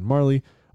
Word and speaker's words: Majerle: If you're not familiar with who Dan Marley is, I only learned recently how to pronounce the name. Is Dan Majerle, M-A-J-E-R-L-E. He was a leading scorer at 14.1 points, but it is pronounced Dan Marley, also known as Thunder Dan.